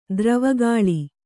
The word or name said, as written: ದ್ರವಗಾಳಿ